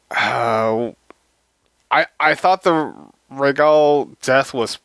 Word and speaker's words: Rhaegal: is it Rhaegal? – yes. Okay. Yes, you're pronouncing it correctly.